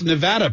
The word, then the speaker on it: Nevada: Are you impressed that I pronounced Nevada properly? – I am.